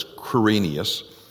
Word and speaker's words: Quirinius: And this taxing was first made when Cyrenius," some pronounce that as Quirinius, "was governor of Syria, and all went to be taxed, everyone into his own city."